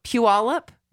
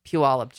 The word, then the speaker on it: Puyallup: I told Lyle earlier, I can't look at how that word is spelled. I just have to know Puyallup. Just say Puyallup, because it's like P-U-Y-A-L-L-U-P.